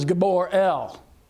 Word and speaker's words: Gabriel: His name is spelled G-B-R-E-L, the Hebrew language, of course, being devoid of vowels. And so the way that that is pronounced is Gabor El.